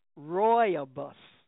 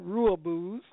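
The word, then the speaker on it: Rooibos: He said, that T is pronounced Roybus. He said, Roybus, not Ruibus.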